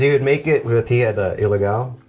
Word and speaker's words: Ilegal: It's not pronounced illegal. – Right. – It's pronounced ilegal because it's Mexican.